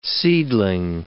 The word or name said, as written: seedling